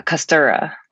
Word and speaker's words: Kostura: Today we have Amanda Castro. Did I say that right, Amanda? – Uh, Kostura.